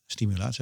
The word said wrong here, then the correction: stimulatoren: zeg ik dat goed? – Stimulatoren, ja.